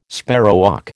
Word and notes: sparrowhawk: American English

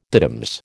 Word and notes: diddums: American English

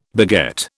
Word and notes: beget: British English

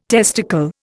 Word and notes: testicle: Indian

